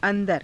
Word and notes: a^dara: Hindi

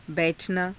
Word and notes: bai_thanaa: hindi

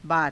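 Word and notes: बात: baata

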